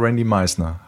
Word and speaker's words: Randy Meisner: Und das letzte Album mit dem Bassisten und Sänger Randy Meisner.